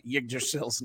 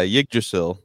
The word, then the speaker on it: Yggdrasil: And our visual audience is seeing, you said it so nicely. Why don't you say Yggdrasil's name? How did you say it? – Yeah, Yggdrasil. – Yggdrasil. All right.